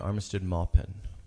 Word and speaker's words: Armistead Maupin: Two other local big names, Amy Tan and Armistead Maupin. Am I saying his name right? – Yes.